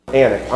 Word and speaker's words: Anak: After they talked about what they'd seen, at the end of it, they said, "We saw the children of Anak there, Anak. Anak is how you pronounce it in Hebrew.